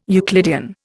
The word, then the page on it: Euclidean: Indian